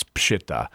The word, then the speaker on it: Peshitta: It's called the Peshitta, and although you'll sometimes see it, people pronounce it Peshitta. But to be technically proper. It's Peshitta.